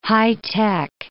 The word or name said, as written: high-tech